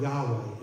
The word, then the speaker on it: Yahweh: And we don't know exactly how we pronounce it, but most people in Hebrew would say Yahweh.